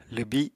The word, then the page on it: lwbi: Apatani